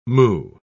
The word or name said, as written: moue